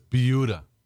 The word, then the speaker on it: Buda: It's not Buda, it's Buda.